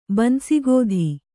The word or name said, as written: ಬನಸಿಗೋದಿ